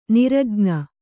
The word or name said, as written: ನಿರಜ್ಞೆ